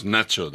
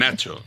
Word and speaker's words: Nacho: You must use Nacho. That is my nickname, and it's very more convenient. Nacho, like nachos.